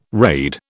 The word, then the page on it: raid: British English